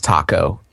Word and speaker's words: Taako: Named uh, uh, the my name is spelled T A A K O. It's pronounced taco, and uh, I'm a wizard named Taco, who you know he's just pretty much standard elven wizard guy.